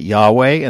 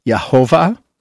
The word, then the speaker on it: Yahweh: Many academics pronounce it Yahweh, and that's sort of the standard traditional approach, so that's why I typically adopt that pronunciation. It's also been pronounced Yehovah as a substitute word for the Tetragrammaton.